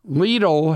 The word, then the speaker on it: Lidl: L-I-D-L, a store that I've shopped at forever in Europe. Lidl has come to the United States. L-I-D-L, it's spelled Lidl, but it pronounces like beetle, but with an L.